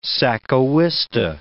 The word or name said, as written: sacahuista